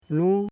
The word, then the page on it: nu: Koro